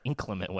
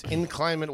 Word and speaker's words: inclement: It's inclement weather. – Yeah, that's the. I think that's the origin of the word, but it's pronounced inclement. Inclement weather. – No, it's not. – It's in climate. okay.